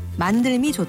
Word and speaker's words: 만듦: 발음할 때도 삶이 힘들다, 만듦이 좋다, 힘듦이 있다처럼 리을 발음을 살립니다.